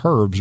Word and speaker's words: herb: But it also covers edible landscape plants, edible weeds, edible plants that normally wouldn't be put in the category of herbs. But my definition of a herb, and that is the way the word should be pronounced, by the way, the definition of a herb to me is a plant that has a use other than looking pretty.